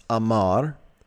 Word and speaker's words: amar: It's related to this word pronounced amar, which means to speak.